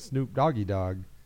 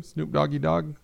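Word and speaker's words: Snoop Doggy Dogg: It's not his name, Snoop Doggy Dog. I thought Snoop Doggy Dog was his name. – No? But just it's like, you say it like Oggy Doggy.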